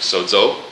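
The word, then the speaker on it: sozo (σώζω): I click on, this is Strong's number, and I actually can learn how to pronounce it. It's actually sozo, and it's going to be the word that's translated.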